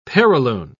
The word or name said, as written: perilune